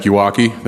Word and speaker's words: Yawakie: My name is Patrick Iwaki. That's spelled Y-A-W-A-K-I-E.